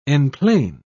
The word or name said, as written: enplane